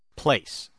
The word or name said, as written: place